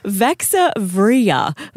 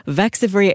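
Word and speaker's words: Vaxzevria: Vaxavria. Vaxavria. It's like Peter Piper picked a peck of pickled peppers. – I haven't heard that in a while. Vaxavria.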